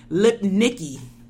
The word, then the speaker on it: Lipnicki: I got his name wrong. His name is Jonathan Lipnicki. – Okay, Jonathan Lipnicki. – Lipnicki.